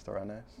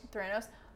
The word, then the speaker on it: Theranos: I'm probably not even pronouncing it right. Um, I'm pretty sure it's Theranos. – Theranos,